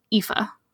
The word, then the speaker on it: Aoife: And I can say that because I am of Irish descent, because holy crap, Siobhan, like S-I-O-B-A-N? That's not right. I really like Aoife, but that's spelled like E-O-I-F-E or A-E-O-I-F-E, and you pronounce it Aoife.